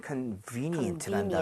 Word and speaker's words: convenient: 그런 것들은 convenient라는 단어. ATM 같은 것은 ATM은 아, 그 옆에 있으면 마음이 편안해지고 이게 아니죠? 네. 편리한 거잖아요. – 그렇죠, 그렇죠. – Convenient. V-E에 강세를 짓고 발음하면 되겠습니다.